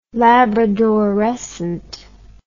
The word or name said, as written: labradorescent